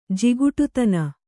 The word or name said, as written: ಜಿಗುಟುತನ